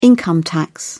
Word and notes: income tax: British English

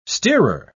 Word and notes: steerer: British English

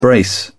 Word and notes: brace: British English

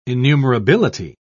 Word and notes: innumerability: British English

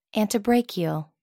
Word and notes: antebrachial: American English

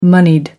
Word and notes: moneyed: American English

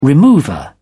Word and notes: remover: British English

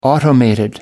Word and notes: automated: British English

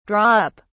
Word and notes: draw up: British English

